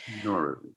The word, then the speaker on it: Nowruz: And the pronunciation is like no, which is new. Ruz, and Ruz is day. So it's Nowruz, but the way we write it in English is Nowruz, Nowruz. – Nowruz.